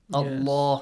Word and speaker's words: Allah: Allah. Yes. Okay. وما الله. Okay. من دون الله فيسبوا. Again, the word Allah is pronounced heavy.